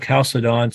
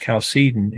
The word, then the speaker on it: Chalcedon: And the Council of Chalcedon, sometimes pronounced Chalcedon, in 451.